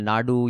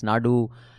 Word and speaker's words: Nadu: and yet you mispronounce Tamil Nadu. You say Tamil Nadu and it's Nad, then someone says it's not Nad, it's Nadu, and I get shamed a lot for being an improper Tamil.